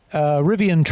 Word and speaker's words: Rivian: Rivian? Is that right? – Rivian trucks, yes.